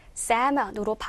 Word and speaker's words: salmon: 자, 이 단어, 왜 우리 레스토랑 같은 데 가면은 연어, 그 메뉴에 보면 이렇게 살몬 이렇게도 많이 있더라고요. 그런데 영어 발음에서는요, 맞아요. 이 L 발음이 나지 않아요. 그래서 salmon으로 발음을 하시면 됩니다.